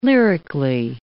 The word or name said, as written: lyrically